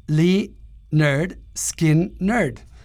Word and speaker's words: Lynyrd Skynyrd: Y ahora nos dimos cuenta de que, claro, su primer disco de 1973 se llama Pronounce Lynyrd Skynyrd. Entonces, ellos mismos nos estaban diciendo y no nos acordábamos que, claro, el primer álbum así se llama. Para los que dudaban, ahí está. El título del álbum es cómo pronunciar el nombre de la banda.